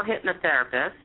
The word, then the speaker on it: hypnotherapist: hypotherapist. How do you say that? – Certified clinical hypnotherapist.